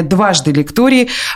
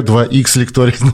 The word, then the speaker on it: Дважды лектории: Вот, да, наконец-то ты хоть прочитала, как же это читается. «Дважды лектория», ты все 2Х, 2Х лектория пытаешься.